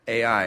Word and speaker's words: Ai: It's actually spelled Ai, and it's pronounced Ai. I actually had to look that up. I'm like, A? Is that it? No. It was Ai.